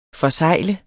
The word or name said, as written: forsegle